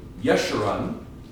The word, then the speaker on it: Jeshurun: And we have this word, Jeshurun, I think is how it's pronounced, but I'm not sure. And this is from the Hebrew word Yashar. So Yeshurun is how it's pronounced in Hebrew.